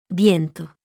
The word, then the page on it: v: Spanish